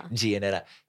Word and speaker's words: Giannetta: Giannetta.